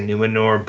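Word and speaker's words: Númenor: It is, I don't know if I'm pronouncing this correct, but it's N-U-M-E-N-O-R. So I would say Numenor, but. – Yeah, Numenor. I would too.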